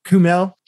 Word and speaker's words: Kumail: One thing I thought somebody would bring up that nobody did that I thought was funny was Kumail, how do you say his name? Is it Kumail or Kunail? Kumail.